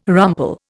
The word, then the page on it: rumple: Indian